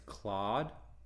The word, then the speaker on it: Claud: I believe the artist's name is Claude, C-L-A-U-D, mm-hmm.